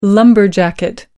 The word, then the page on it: kiwi fruit: British English